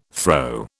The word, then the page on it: throw: British English